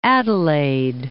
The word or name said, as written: Adelaide